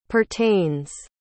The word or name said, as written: pertains